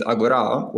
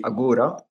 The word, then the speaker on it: Agora: And probably the first thing that we will need to address about this new format is the standard pronunciation. As in the native language is Agora, while in English speakers, probably they will say Agora.